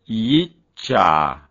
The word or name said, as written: gyttja